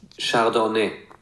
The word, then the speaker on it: Chardonnay: Chardonnay steht auch im Duden und es gibt eine deutsche Aussprache für das Wort. Wir müssen das nicht französisch aussprechen. – Chardonnay. – Das ist die deutsche Aussprache von Chardonnay. – Ja. – Wir haben eine ganz leichte Betonung.